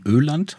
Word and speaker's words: Öland: Sie haben ja gemeinsam mit Ihrer Frau einen ökologischen Ferienbauernhof in Schweden, in Öland.